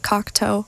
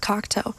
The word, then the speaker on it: Cocteau: Even though I know how it's pronounced, I know it's pronounced Cocteau. I think. No, it is. It is. But somehow, just the nerves get to me. I say Cocteau. I'm like, oh no, it's not right.